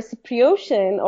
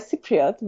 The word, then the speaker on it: Cypriot: But because time does move differently in Cyprus, like 15 ta 15 yeah 15 minutes, I know it does sound funny, but for a Cypriotian or a Cypriot, which is the correct way to pronounce it, like 15 minutes Thanks for that.